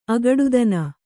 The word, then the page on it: ಅಗಡುತನ: Kannada